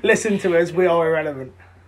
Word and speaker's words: IRRLVNT: Yeah, we are Irrelevant, spelled I-R-R-L-V-N-T, pronounced Irrelevant.